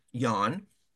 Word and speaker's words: Jon: And so eventually they self-selected amongst the three of them and put forward a great candidate, Jan, spelled J-O-N, but pronounced Jan, Oscarson, I think.